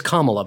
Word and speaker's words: Kamala: And it is Kamala, by the way, C-O-M-A, comma, and then La, because I was pronouncing it wrong as well, like many other people.